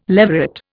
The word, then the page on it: leveret: Indian